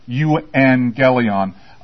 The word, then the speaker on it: euangelion: You see these right here? You see those two together? It's pronounced ing, right? So, euangelion.